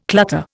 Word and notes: clutter: Indian

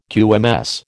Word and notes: QMS: American English

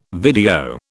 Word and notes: video: British English

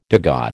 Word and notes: degas: American English

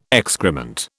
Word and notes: excrement: British English

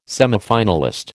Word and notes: semifinalist: American English

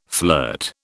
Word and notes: flirt: British English

